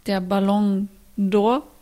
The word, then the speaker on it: Ballon d'Or: Es war jetzt der Ballon d'Or. Josie, hab ich es richtig ausgesprochen? – Super. Mhm. Genau, ein bisschen schneller, ein bisschen flüssiger.